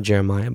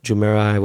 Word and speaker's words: Jumeirah: It's not Jeremiah, but Jumeirah, whatever.